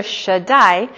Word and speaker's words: Shaddai: Shaddai, it means enough.